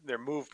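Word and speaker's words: MUVE: All right, they have their Move product. It's lowercase M, capital U, capital V, lowercase E. Move. That's how it's spelled.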